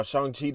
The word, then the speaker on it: Shang-Chi: Shang-Chi today, because apparently that's how you're supposed to say it, and I've been saying it wrong this whole entire time, and I take full blame for that.